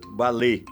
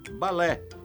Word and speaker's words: balé: A pronúncia correta em português é aberta, balé. Acho que nosso cantor pensou que, por se tratar de palavra francesa em que o E, com acento agudo, é pronunciado fechado, devesse também dizer balé. Equivocou-se. O certo é mesmo balé.